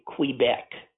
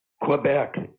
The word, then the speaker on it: Quebec: Laurent Duvernay Tardif, and uh, he, he he's from Quebec, or as we say, Quebec, right? Quebec, yeah. Quebec. and he has the most amazing story um, while he was playing in the NFL, he went to medical school.